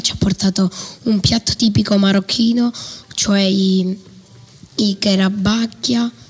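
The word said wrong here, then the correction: chebakia: Come si pronunciano? – Allora, shbekia. – Ah, come? – Sono difficile. – Provo a ripetere, vai. – Shebekia. – Meglio? Meglio, sì.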